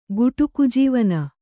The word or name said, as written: ಗುಟುಕುಜೀವನ